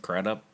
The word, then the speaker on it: Crudup: But I wanted Billy Crudup to win. Is that how you say his name? – Mm-hmm. – Crudup? – Mm-hmm.